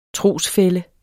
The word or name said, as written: trosfælle